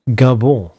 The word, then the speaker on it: Gabon: Nästa afrikanska land till rakning är Gabon, eller kanske Gabon, som man kanske säger med ett mer sofistikerat uttal.